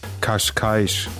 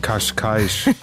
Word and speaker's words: Cascais: Cascais. – Nochmal für alle? – Cascais. – Und im ganz kleinen Kreis, wohl ganz intim und privat. – Aber sag mal, Cascais, war das nicht ein Auto? – Cascais.